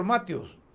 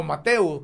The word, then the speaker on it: Matheu: Mire, si el ministro lo único que tiene es que se embreca, hombre el ministro Mateus, o Mateus, como usted quiere decirle, pero se escribe con M-A-T-H-E-U. Mateus o Mateo.